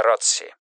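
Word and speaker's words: ROTC: Ротс произносится Ротси. Программа для тренировки офицеров резерва.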